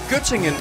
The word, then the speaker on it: Göttingen: Var det ved Gødringen? – Nej, Göttingen. – Göttingen, ja, men det er... – Göttingen ost. – Jamen, det er bare mig, der ikke kan udtale det korrekt.